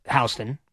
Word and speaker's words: Houston: But yes, I will be out at Blue Haven West on Houston Street, spelled Houston, but on Houston. It's pronounced Houston, but it's spelled Houston.